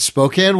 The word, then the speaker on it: Spokane: The name, the, the the pronunciation Spokane really bugs the hell out of me. The city is called Spokane. It's Spokane, Washington. Nobody says Spokane, Washington.